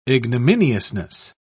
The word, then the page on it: ignominiousness: British English